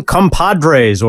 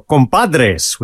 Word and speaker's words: Compadres: That was Chick Corea with his composition Compadres, or Compadres, with the Spanish pronunciation, from Touchstone in 1982.